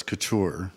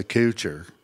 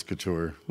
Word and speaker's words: Couture: Well, the right way to pronounce it is the way Jeremy does, Couture. – Uh-huh. – It's a French word. – Uh-huh. – But when we moved here to Texas, West Texans couldn't say that. And so we kind of acquiesced to Couture.